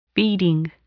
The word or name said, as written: feeding